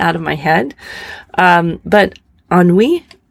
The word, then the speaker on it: ennui: But I think it's, you pronounce it Inui. I know I'm wrong, but I see how it's spelled E-N-N-U-I. And I'm like, oh, this word, what is this word? And so I'm fixated on this word and I finally Google it. Thank goodness for Google. And it is pronounced Anui.